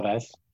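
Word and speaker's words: Alavés: I said that right? – Alaves. Yeah. – Over to our Spanish correspondent there for the correct pronunciation.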